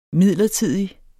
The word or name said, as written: midlertidig